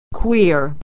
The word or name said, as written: queer